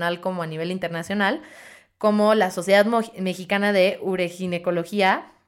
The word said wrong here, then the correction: uroginecología: uroginecología, madre mía, imagínense, o sea, yo jamás me podría graduar de ahí, no lo puedo ni pronunciar.